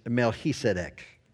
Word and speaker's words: Melchizedek: But I will tell you that the actual pronunciation of this obscure figure is Melchizedek.